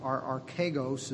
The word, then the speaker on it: archegos: archegos is how I would pronounce it, carries with it the word that we would recognize, and that's arch.